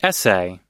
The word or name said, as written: essay